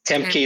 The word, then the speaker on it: Tam Ky: Am I even saying it right? Like Tam Ky is fine, and both of us are going to be saying all of the words incorrectly.